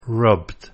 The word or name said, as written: rubbed